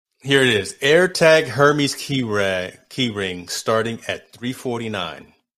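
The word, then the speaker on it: Hermès: Outside of and apparently the H is silent. Hermes. See, I rolled the R's there. – Hermes.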